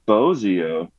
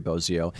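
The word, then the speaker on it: Bozzio: Is it Bozio or Bozio? What do you say? – I think it's Bozio.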